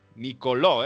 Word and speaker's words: Nicolò: Nicolò, eh, que el acento en la O final.